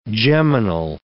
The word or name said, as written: geminal